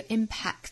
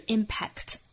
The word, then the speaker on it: impact: 那我们还要注意一个词啊，就是中间的这个 impact 这个词，中间的 k 的发音不要爆破十足，不是 impact。而是要憋这么一下，注意是两个爆破音在同一个单词之内出现了，